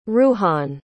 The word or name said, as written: ruhan